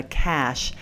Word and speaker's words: Cache: It's spelled C-A-C-H-E, Cache.